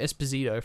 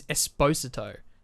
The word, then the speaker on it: Esposito: I've been calling him Giancarlo Esposito for God knows how many years now. Nearly 10, maybe, since I started watching Breaking Bad. Apparently, his name is Esposito. That's how you pronounce it. I only just found this out a couple of weeks ago. Esposito.